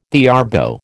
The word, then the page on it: theorbo: American English